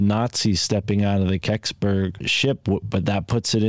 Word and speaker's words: Kecksburg: If anyone looks it up on Wikipedia, it's spelled K-E-C-K-S-B-U-R-G. Incident, Keksberg incident, and that's Keksberg, Pennsylvania.